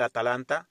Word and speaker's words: Atalanta: La pronunciación siempre la confundo, pero esta vez la dije bien, el Atalanta de Dubán Zapata y Luis Fernando Muriel.